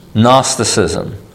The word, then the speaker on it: Gnosticism: Gnosticism, spelled like Nockies, GN, Gnosticism.